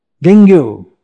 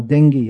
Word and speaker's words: dengue: We have diseases like dengue, or dengue as it's called in English. Well, in India it's called dengue. It's spelt like that.